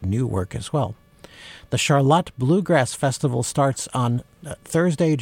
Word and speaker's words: Charlotte: It's spelled the way Charlotte is, but it is pronounced Charlotte. And that's one of those words, you know, like when you have Milan and Milan. If you're from central Michigan, if people say Charlotte, they know you're local.